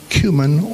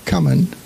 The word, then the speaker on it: cumin: You give a tenth of your spices, And then he names three: mint, dill, and cumin, or cumin, depending on how you choose to pronounce it.